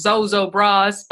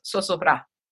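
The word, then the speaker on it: Zozobra: So I'm so grateful that you are here to help us Americans understand this little thing called Zozo bras, but you said it's pronounced Sosobra.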